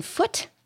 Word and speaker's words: Foote: Screenplay written by Horton Foote, I think is the way you pronounce his name, F-O-O-T-E. – Foote, yes.